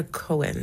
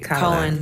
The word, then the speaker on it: Cowan: Is it Cowan or Cohen? – You actually got it right. – Cowan.